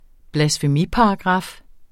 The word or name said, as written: blasfemiparagraf